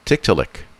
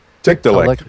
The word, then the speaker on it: Tiktaalik: It is Tiktaalik. Tiktaalik. – Tiktaalik.